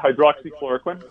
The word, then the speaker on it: hydroxychloroquine: Okay, so if I say remdesivir to you, and I can't remember how to say the other one, hydroxychloroquine? – Yeah, hydroxychloroquine.